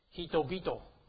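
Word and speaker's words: hito bito (人々): If you write it like this, and it becomes hito bito. What was God's? Do you remember? Kami, kamigami. And people is hito bito.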